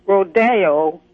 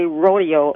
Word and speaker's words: rodeo: They all say rodeo, and it's not correct. – Well, gosh, you know, I've only known rodeo as Rodeo Drive in Beverly Hills, but in every... – Well, that's the correct pronunciation. It's a Spanish word the E's pronounce as an A.